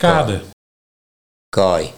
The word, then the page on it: Kaai: Nieuw lekkerlands